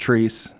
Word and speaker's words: Chreece: It happens in Fountain Square every summer, and it's spelled C-H-R-E-E-C-E. but it's pronounced Trees.